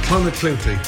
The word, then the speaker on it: Clonakilty: Irish tea bags or Clonakilty sausages from Cork. – Clonakilty. – Clonakilty. – Clonakilty, thank you. – He can cook them, but he can't pronounce them.